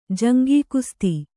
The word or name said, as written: ಜಂಗೀಕುಸ್ತಿ